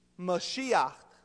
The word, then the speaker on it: Mashiach: Now, and everybody that is anointed by olive oil, that action of anointing in Hebrew means Mashiach.